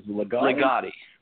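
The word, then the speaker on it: Ligotti: Legati.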